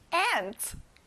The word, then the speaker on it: ant: 개미, ant. 앞에 A를 A 소리로 내야 돼요.